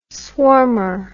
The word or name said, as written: swarmer